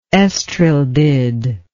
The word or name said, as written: estrildid